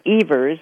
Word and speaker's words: Evers: And people say, it's sort of Evers, is the Dutch, it's a Dutch pronunciation.